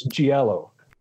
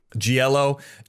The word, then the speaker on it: giallo: I've got Psycho Bitty, Galeo, which I think is, I'm not pronouncing that's Italian. – I think it's Giallo. – Giallo, eco horror, folk horror, luchador films, rancho gothic, splatter.